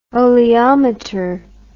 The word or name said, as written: oleometer